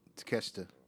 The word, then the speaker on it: Takeshita: So we started off the night with MJF versus Konosuke Takeshita. Did I say that right? – Takeshita.